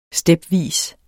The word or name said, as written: stepvis